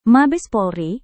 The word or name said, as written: mabes polri